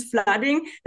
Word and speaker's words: Flooding: habe es vorhin falsch gesagt, Flooding, oder? – machen Ja. Ich und die englische Betonung. Also, dann machen wir dieses Flooding.